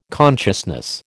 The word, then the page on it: consciousness: American English